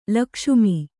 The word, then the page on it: ಲಕ್ಷ್ಮ: lakṣma